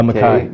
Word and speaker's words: Mackay: I, I, I, saw on the website Mackey Creek. Is that how you say it? – Uh, Mackay. Mackay. Yeah.